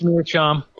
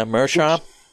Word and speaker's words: Meerschaum: Unless I'm saying that wrong. – Vintage Fleshlight? – No, it's Meerschaum. – Yeah, Meerschaum.